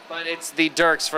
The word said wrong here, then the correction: Daleks: or whatever that freaking thing is from Doctor Who that we always do. Daleks. Yeah, Daleks. I suck, okay? I can't pronounce to save my life. You know this? Daleks.